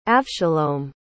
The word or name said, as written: avshalom